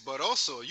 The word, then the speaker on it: Barroso: Well, that goes to show what you think of him, because it's Barroso. You calling him Basuro. – Oh, it's Barroso. Okay. I thought it was Barroso. I first said, Barroso. – Nah, again, you say it like garbage. You said Basuro.